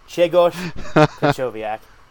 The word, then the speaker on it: Grzegorz Krychowiak: Grzegorz Krychowiak.